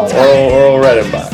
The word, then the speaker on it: Orville Redenbacher: I said it right, right? – No! – Orville Redenbacher.